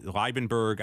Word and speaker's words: Liebenberg: So you have guys like, I probably mispronounced his name before because it's very Germanic, but it's Liebenberg out of Pretoria, Africa.